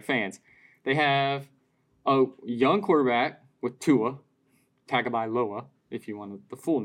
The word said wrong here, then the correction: Tagovailoa: Pronounce Tua's last name. – Tagovailoa. – Yeah, there it is.